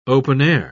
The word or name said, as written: open-air